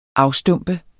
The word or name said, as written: afstumpet